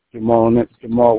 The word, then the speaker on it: Jamal: Jamal Williams. – Can you spell it? – J-A-M-A-L. Williams, W-I-L-L-I-A-M-S.